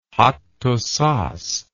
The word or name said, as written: Hattusas